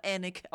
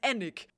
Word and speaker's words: Alnwick: Es wird Alnwick geschrieben, aber Annick ausgesprochen, weil keine Ahnung, diese verdammten Briten mir das Leben schwer machen wollen und sie ihre Schlösser und Kathedralen einfach anders aussprechen, als sie sie schreiben. Gloucester heißt Gloucester Ragnar. Alnwick heißt Annick.